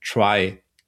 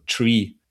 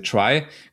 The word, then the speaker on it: Trie: Einen kleinen Baumausflug möchte ich noch machen, und zwar den sogenannten Try, geschrieben T-R-I-E, also Tree. Aber aussprechen tut man es üblicherweise Try, kommt von Retrieval, Information Retrieval, Text Retrieval.